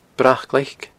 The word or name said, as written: Brac Ghlaic